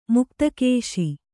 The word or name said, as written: ಮುಕ್ತಕೇಶಿ